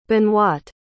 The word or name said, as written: Benoite